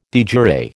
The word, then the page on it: de jure: American English